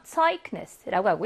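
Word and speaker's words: Zeugnis: 철자가 자음이고 그 앞에 기가 위치할 때는 크 발음을 냅니다. 자음 앞에 있어서 Zeugnis라고 하고요.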